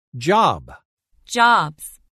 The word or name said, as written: Job – Jobs